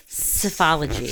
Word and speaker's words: psephology: All right, it is P-S-E-P-H-O-L-O-G-Y. So it's cephalogy.